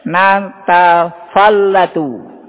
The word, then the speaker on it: natafallatu: Bacanya adalah natafallatu. Natafallatu, fa-nya didahulukan kemudian lam-nya.